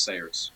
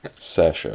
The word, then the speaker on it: Saoirse: I've been told time and again that it was Sayers. – No. Saoirse. – Better than going like Saoirse Ronan. – Saoirse.